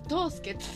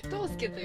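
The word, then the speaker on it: 藤助: 書くと藤藤の花の藤に「助ける」と書いて「とうすけ」とうすけと呼びますな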